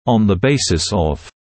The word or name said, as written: on the basis of